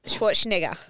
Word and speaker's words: Schwarzenegger: No, can I just say that I knew Arnie Schwarzenegger, whatever his last name is. Schwarzenegger, I don't know. – That was close. Try again. – Is that right? Schwarzenegger. – Yes. There you go.